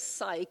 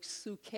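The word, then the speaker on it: suke (ψυχή): Like it says psych. It's pronounced apparently in Greek, suke.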